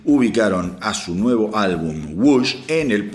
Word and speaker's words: Whoosh: Los Deftones le ponen OMS, que se escribe O-H-M-S. Deep Purple le pone Whoosh, W-H-O-O-S-H, -O sea, OMS, Whoosh ping, pum, cabón.